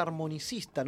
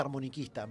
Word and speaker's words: armonicista: Em, a lo que voy es que eh, hoy por hoy, o como le decimos a la gente, usted es armonicista, no se dice armoniquista.